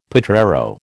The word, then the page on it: potrero: American English